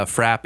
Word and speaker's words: frappe: It's wrong for around here, but a frap is the way it's pronounced on the East Coast. – You mean for a frappuccino? – For a frappe.